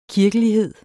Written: kirkelighed

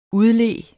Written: udlede

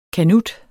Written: kanut

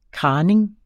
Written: kraning

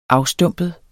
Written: afstumpet

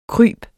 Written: kryb

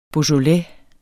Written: beaujolais

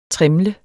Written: trimle

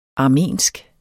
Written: armensk

